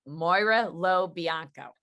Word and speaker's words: Moira Lo Bianco: Welcome back to The Beats today with Kelly Kennedy and one of my dear honored guests, Moira LeBlanc Bianco. Moira Lo Bianco. Did I say it right? – Moira Lo Bianco. That's perfect.